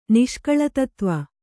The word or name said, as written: ನಿಷ್ಕಳತತ್ವ